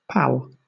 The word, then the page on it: pal: British English